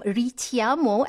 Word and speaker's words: Ritiamo: And yes, the name Ritiamo, actually pronounced as Ritiamo, it is an Italian word translated to call.